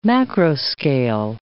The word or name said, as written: macroscale